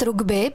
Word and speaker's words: rugby: Tak správně by mělo být rugby, protože to je z angličtiny, ale tady u nás v Čechách používáme obě varianty a není to vůbec špatně, ale vyslovuje se samozřejmě rugby.